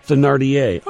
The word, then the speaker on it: Thénardier: Madame Thénardier. Am I pronouncing that properly? I feel like I'm butchering it every time I say it. – Okay. – Say it again. – Thénardier.